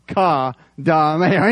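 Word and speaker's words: akadameo (οἰκοδομέω): And I said that right because I spelled it out. Akadameo.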